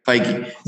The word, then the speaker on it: Feige: Feige. Yeah. – Feige.